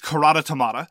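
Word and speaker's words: Karate Tomate: I'm going to call it Karate Tomati. In German, it's Karate Tomata. I don't want to call it that because neither of those are English pronunciations.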